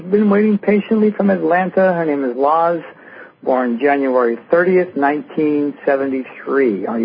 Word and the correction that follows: Luz: Am I pronouncing your name correctly? – My name is Luz. – Luz.